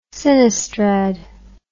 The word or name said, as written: sinistrad